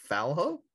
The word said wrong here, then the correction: Fialho: Fialho, I would say. – Fialho? – Okay.